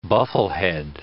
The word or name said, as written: bufflehead